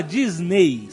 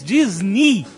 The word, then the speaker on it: Disney: O EY no final da palavra de Disney, na verdade não se fala Disney, se fala em inglês, na pronúncia em inglês, Disney. Tem o som de I.